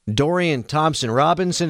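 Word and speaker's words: Dorian Thompson-Robinson: Steelers taking on the Browns on Thursday, where they are 1-4-1 in their last six in Cleveland, including losses to Jacoby Brissett and Dorian Thompson Robinson.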